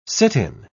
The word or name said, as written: sit-in